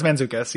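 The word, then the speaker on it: Mantzoukas: I might have said his last name wrong, so forgive me, everybody. – Okay. No, yeah, it's Manzuka's.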